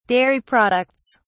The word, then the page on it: dairy products: British English